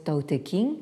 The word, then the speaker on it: Tao Te Ching: en Occident le Tao Te Ching, hein, qu'on prononce quelquefois à la française Tao Te King, hein, euh, euh, le livre canonique de la voix et de sa vertu ou de sa puissance.